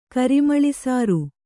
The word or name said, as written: ಕರಿಮಳಿಸಾರು